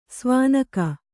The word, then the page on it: ಸೋವನಿಗ: sōvaniga